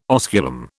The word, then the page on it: osculum: British English